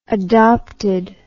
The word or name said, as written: adopted